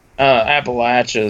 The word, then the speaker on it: Appalachia: But I mean, when I read, like, do you pronounce it Appalachia or Appalachia? – Appalachia is how, like, the natives pronounce it, one's correct as well.